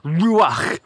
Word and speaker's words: Ruach: Now, the Hebrew, which is the language that the Old Testament was originally written in, uses this word to describe the Spirit, Ruach, which, if you're not spitting in front of you, you're not actually saying it right, but it's the same word that the Old Testament uses to describe breath or wind.